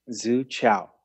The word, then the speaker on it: Zu Qiao: So now we're up to an energy center called the Zu Qiao, and that is spelled Z-U, and that is in Mandarin, Qiao, Q-I-A-O, Zu Qiao.